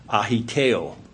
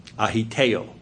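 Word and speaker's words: aiteo: But if you look up the original Greek of the term being translated ask here in Matthew 7, verse 7, it's a Greek word that is spelled in the English A-I-T-E-O, A-I-T-E-O. It's pronounced ahiteo, ahiteo.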